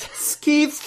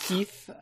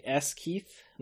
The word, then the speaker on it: Skeeth: So this is a infinite Jenny specifically deck that was put up on ArkhamDB by Skeeth, which is Skeeth. – Skeeth, I believe is how you say his name. I thought maybe it was just like S Keith, like Super Keith or something, but I don't know.